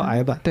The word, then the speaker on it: 呆板: 你记不记得上学的时候，呆板它其实发音叫矮板。